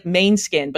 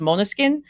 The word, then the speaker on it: Måneskin: Their name looks like Maneskin, but it's pronounced Måneskin.